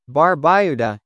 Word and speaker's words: Barbuda: Antigua, pronounced Antigua, and Barbuda, Barbuda, is located in the heart of the Caribbean Sea.